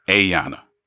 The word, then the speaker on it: Ayana: A-Yana. Long A. Yana.